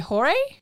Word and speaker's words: Jorge: I had to write because so one of the leaders of the cartel is, his name is written like George, but it's actually Jorge. – Yeah. – And so I had to write it down because I'm like, you're going to say George like a fucking asshole. That's not how it's pronounced.